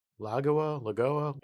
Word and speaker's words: Lagoa: The chatter is it's going to be Amy Coney Barrett, ACB, or Barbara, I'm going to pronounce this hopefully right, Lagoa. – I think that's right.